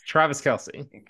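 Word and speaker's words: Travis Kelce: Yes, Travis Kelsey.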